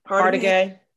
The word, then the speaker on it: Hargitay: Hargitay.